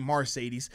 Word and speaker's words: Mercedes: I like the car Mercedes, but he pronounced his name Mercedes Lewis.